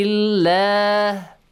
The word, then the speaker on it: الله: okay now به الله again the lam and the word Allah is pronounced with a light sound به الله that's it فَيَغْغَيْن has a heavy sound.